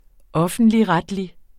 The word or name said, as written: offentligretlig